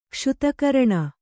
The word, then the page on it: ಕ್ಷುತಕರಣ: kannada